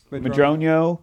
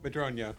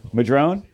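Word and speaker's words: Madroño: How do you pronounce it? Madrono. – Madrono. – Madrone? Is it just Madrone? – It's Madrono, yeah.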